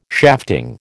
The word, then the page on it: shafting: American English